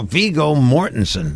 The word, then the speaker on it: Viggo Mortensen: Viggo Mortensen.